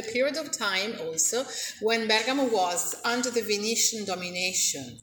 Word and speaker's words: Bergamo: You have to pronounce it Bergamo. So the stress is on the first syllable, Bergamo.